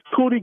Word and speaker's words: Kudee: Oh, my name's Cootie Garley. – How do you say your first name? – K-U-D-E-E, last name Garley, G-A-R-L-E-Y.